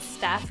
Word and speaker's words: Stef: Yeah, I get Stefani a lot. That's not how you say it.